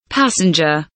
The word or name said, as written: passenger